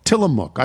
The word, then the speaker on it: Tillamook: The mook part is pronounced like cook. Tillamook.